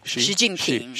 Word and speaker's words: Xi Jinping: Is that how you pronounce his name? – X-U. – X-I. – What's the name? – Xi. – Xi? – Xi Jinping. – Xi Jinping, yeah.